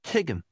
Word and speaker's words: tigim: Here's how to say, I understand. Listen and repeat. – Tigim. Tigim. Tigim. Tigim. – Did you hear the t sound at the beginning and the m ending?